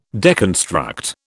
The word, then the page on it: deconstruct: British English